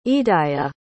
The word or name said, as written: idaya